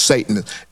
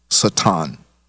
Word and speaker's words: Satan: It's not pronounced Satan. It's pronounced Satan.